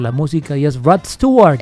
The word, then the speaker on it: Stewart: Ah, Rod Stewart.